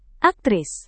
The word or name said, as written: aktris